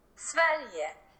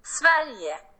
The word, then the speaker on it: Sverige: It's not pronounced that. It's pronounced like this. – Sverige.